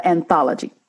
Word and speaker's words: anthology: And she was actually at that time putting together, an anology. Is that what it is when it's several authors? Am I pronouncing it correctly? – I believe it's an anthology.